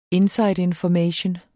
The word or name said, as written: information